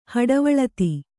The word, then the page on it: ಹಡೆವಳಿತಿ: kannada